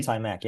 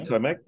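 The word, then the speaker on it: Eitay Mack: I'm not sure if I'm pronouncing that correctly. – Itay Mack, yeah, that's the one. – Itay Mack, yep.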